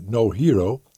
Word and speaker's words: No Hero: En aan de telefoon is nu de heer Erik Lips en hij is verbonden aan het museum No Hero. Ik geloof dat ik het op zijn Engels moet uitspreken. Klopt dat, meneer Lips? – Ja, dat klopt.